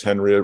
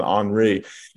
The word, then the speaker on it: Henri: And of course, he's French, but we pronounce his name American style as Henry rather than Henri.